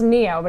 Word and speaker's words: NIO: Neo, it's pronounced Neo, but it's spelled N-I-O cocktails.